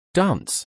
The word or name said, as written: Dance